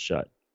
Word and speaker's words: Schutt: It's shut. S-C-H-U-T-T. – S-C-H-U-T-T.